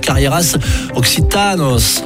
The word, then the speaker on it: Carrieras Occitanas: Et là, oh, je me fais taper sur le bout des doigts, on dit pas comme ça, ça se prononce Carrieras Occitanos. Voilà, avec un accent sur le petit A et os à la fin.